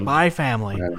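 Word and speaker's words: Spy × Family: Yeah, do we not even say the X, like Hunter Hunter? – Nope. – Spy Family. – Spy Family. The X is silent.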